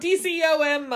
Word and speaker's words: DCOM: Oh, is it time for the DOCM month eventually? I said that wrong. DCOM month.